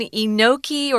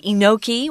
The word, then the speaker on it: enoki: Uh, there's one kind, the last one, enoki or enoki. Uh, it's pronounced either way. We're going to say enoki when we read.